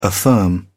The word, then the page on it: affirm: British English